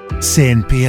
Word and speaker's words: CNP: Si legge SNP, si scrive CNP.